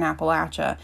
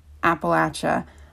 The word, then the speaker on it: Appalachia: I'm from Asheville, North Carolina, so I'm kind of from a good area within Southern Appalachia, and it is pronounced Appalachia. I have heard many times Appalachia, and that is just not correct.